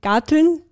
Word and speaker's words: Garteln: Also weil wir vorher im Vorgespräch kurz darauf gekommen sind, genau, persönlich ist Garteln, sagt man das so in Österreich? Habe ich das jetzt gut ausgesprochen? – Ja, genau. Hast du gut.